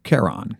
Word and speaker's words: Charon: Yeah, but again, say it again. Charon, Charon. – Charon. Which is spelled, I think, C-H-A-R-O-N.